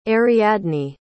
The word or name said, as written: ariadne